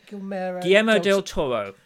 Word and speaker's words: Guillermo del Toro: How do you say his Guillermo del Toro.